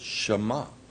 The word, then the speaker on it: Shema: They say Shema, not Shema. I would say Shema, but it's Shema.